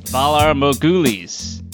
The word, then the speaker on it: Valar Morghulis: Valar Morghulis,